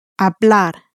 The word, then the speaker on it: hablar: As I said, words ending in a consonant, by contrast, are stressed on the final syllable. So, to speak, which ends in an R, how would you pronounce that? – Hablar.